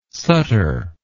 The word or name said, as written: Sutter